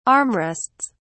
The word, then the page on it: armrests: British English